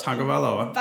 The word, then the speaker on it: Tagovailoa: Tua Tagovailoa. – Tagovailoa.